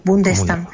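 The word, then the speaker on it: Bondestam: Y Linda von der Stam, esa O se pronuncia como una U, Bundestam.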